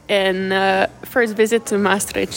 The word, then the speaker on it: Maastricht: Maastricht.